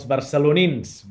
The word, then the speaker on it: barcelonins: See, in Catalan, it's els barcelonins, barcelonins.